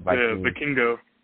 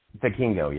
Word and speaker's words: Vikingo: And Viking, how do you pronounce it? – Vikingo. – Vikingo, yeah. Watching Vikingo and Kenny Omega, I mean, and some of the, his athleticism, it was so quick and crisp.